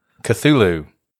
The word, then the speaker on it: Cthulhu: Cthulhu.